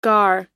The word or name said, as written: gar